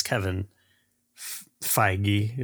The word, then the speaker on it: Feige: It's I guess it's Kevin Feige.